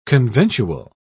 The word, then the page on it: conventual: British English